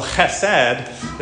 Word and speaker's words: chesed: you just have to learn to kind of make that nasally sound in your throat, that sound, and so chesed is, I think, how they would pronounce it.